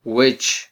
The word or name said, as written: wech